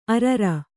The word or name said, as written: ಅರರ